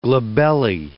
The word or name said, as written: glabellae